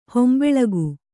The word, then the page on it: ಹಿಂಬಾಲಕ: Kannada